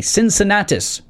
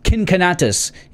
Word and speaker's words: Cincinnatus: In ancient Roman society, Cincinnatus, or Cincinnatus, if you want to use the Latin pronunciation, was a hero among the Romans.